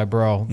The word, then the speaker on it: Breaux: I think it was Bro who came away with the second. You mad, bro? – Which it's so fitting that he plays for New Orleans because his name is spelled B-R-E-A-U-X.